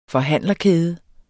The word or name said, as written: forhandlerkæde